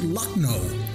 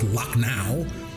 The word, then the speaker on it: Lucknow: I speak Bruce County, not Big City, and I pronounce it Lucknow, not Lucknow.